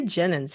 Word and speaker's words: Jenyns: Did I say it right? – Thank you. Yeah, pretty close. – Oh, you'll educate me. So, what is it? – All right. It's Jenyns. – Jenyns. David Jenyns.